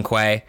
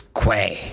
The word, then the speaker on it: Kuei: King Kuei. I found out that that's how his name is pronounced also. I just knew him as the Earth King, but then in the books they gave him a name that I first saw, and I guess it's Kuei.